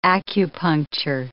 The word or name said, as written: acupuncture